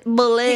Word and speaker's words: bullet: He put the emphasis on the wrong syllable. – No, he didn't. He says bullet. – Okay, wait. – Heather has to say police, like NWA. – What other way is there to say it? – Not police. – Let's hear it again. Let's settle this dispute. He's putting the syllable on the emphasis on the first syllable.